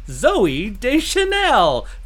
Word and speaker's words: Zooey Deschanel: And so every time I say her name, I have to say it, Zooey Deschanel, because that's how it's said.